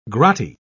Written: grotty

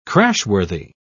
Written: crashworthy

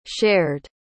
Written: shared

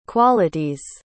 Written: qualities

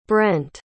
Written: brendt

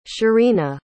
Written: cherina

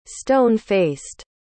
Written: stone-faced

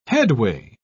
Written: headway

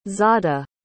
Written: zada

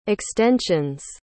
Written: extensions

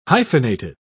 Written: hyphenated

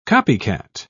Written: copycat

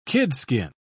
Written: kidskin

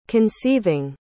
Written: conceiving

